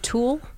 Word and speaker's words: tulle: Oh, that's called tulle? I didn't know that. – It's actually called tulle. – Oh, how do you spell that? – T-U-L-L-E.